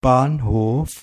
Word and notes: station: /ˈsteɪʃən/

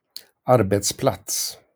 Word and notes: arbetsplats: Sweden (Stockholm); /²arbeːtsˌplats/

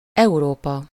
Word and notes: Europe: /ø.ʁɔp/

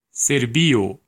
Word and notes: Serbia: /ˈsɜː.bi.ə/; /ˈsɝ.bi.ə/

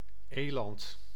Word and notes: eland: /ˈeːlɑnt/

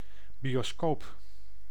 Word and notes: bioscoop: [bjo.skoːp]

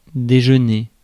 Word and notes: déjeuner: /de.ʒœ.ne/; France (Paris)

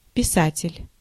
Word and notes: auteur: France; [o.tœʁ]

